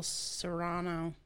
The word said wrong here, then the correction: Cyrano: I said it wrong again. Cyrano. – It's Cyrano.